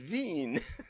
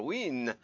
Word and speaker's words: Wien: You know, we don't pronounce Wien. We pronounce Vienna. And why we do, I don't know. But when you're in Vienna, they pronounce it Wien. Or Wien, or something like that.